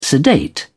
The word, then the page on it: sedate: British English